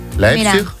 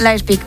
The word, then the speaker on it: Leipzig: Leipzig. Leipzig, ¿ves? – Leipzig, muy bien.